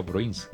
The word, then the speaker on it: Bruins: Bueno, Arriba Bruins, yo no sé si lo estoy pronunciando bien. ¿Cómo se pronuncia, Mercy? – Sí, así se pronuncia. Está muy bien.